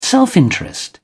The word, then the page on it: self-interest: British English